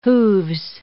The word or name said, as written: hooves